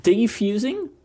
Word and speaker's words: defusing: Is it defusing or is it diffusing? Because you've been pronouncing it diffusing. – Yeah. Defusing. Is that how I... – You've been saying diffusing.